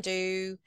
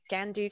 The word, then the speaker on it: Gandu: I personally think it's quite easy to pronounce my surname, but maybe that's just me. It's like quite easy sounding syllables. Mm. Gandu, can do. I used to say Gandu, can do. And then people maybe get it.